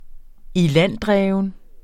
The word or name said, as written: ilanddreven